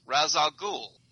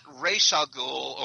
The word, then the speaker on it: Ra's al Ghul: to properly pronounce Ra's al Ghul, not Ra's al Ghul or whatever the CW kids say.